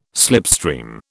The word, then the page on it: slipstream: British English